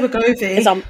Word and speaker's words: Wegovy: Wegovy? How do you say it? – I would say Wegovy.